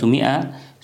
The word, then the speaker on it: مئة: كلمة مئة تكتب ميم أليف همزة تاء مربوطة، أو 200 ميم أليف همزة تاء ياء نون. الألف فيها تكتب ولا تلفظ، ولعلهم كانوا يكتبونها للتفريق بين كلمة مئة ومنه، لأنها تشتبه في اللفظ، أو في الشكل.